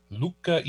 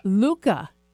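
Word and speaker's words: Lucca: Luca, Italia. – Luca.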